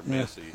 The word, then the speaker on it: Massey: It's like M-A-S-S and a dash with a straight E there, Massey. – Yeah. Exactly. Put the line over the E. All right, so Massey's going to be pitching to Yon.